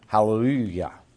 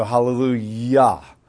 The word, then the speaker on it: hallelujah: And I know we have hallelujah, but remember in the original Hebrew there were Y's and not J's, and that's why we continue to pronounce it Yah, even though it's a Jah.